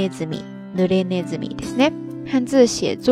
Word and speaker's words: 鼠: 这个单词后半部分的那个“ 鼠” 字读作 “nezumi”，自然意思就是老鼠了。